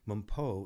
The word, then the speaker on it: Mpho: Mumpo is spelled M-P-H-O, Journey with Mumpo.